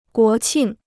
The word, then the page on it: 国庆: guó qìng